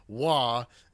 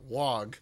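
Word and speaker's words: Waaagh: they pronounce it "wah" and not "wog."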